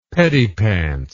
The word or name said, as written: pettipants